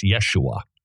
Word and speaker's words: Yeshua: Incidentally, Jesus' name wasn't pronounced Jesus. It was Yeshua. That's how you pronounced his name.